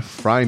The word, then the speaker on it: Frey: Sim, a pronúncia do nome é Fry mesmo. Aqui no Brasil nós adotamos a pronúncia incorreta. Glenn Fry,